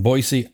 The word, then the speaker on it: Boise: the National Interagency Fire Center in Boise, Idaho. And that is how you say it.